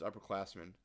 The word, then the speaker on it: UPPRCLSSMN: It's spelled, he's got a specific spelling on it. It's UPPRCLSSMN. But pronounced Upperclassmen, obviously.